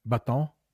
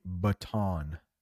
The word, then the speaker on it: baton: Baton is how you say that. In English, I know it's French, right? Baton, a stick. But in English, we say baton, baton.